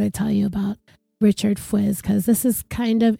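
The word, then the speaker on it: Fuisz: And his name is spelled F-U-I-S-Z. I looked it up and it looks like it's pronounced Fuiz.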